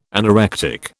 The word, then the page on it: anorectic: British English